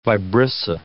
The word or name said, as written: vibrissa